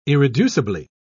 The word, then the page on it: irreducibly: British English